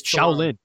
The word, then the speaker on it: Chelan: Chelan, is it Chelan? C-H-E-L-A-N. I'm going to pronounce this. – Shaolin. – Shaolin.